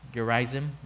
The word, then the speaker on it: Gerizim: Gerizim.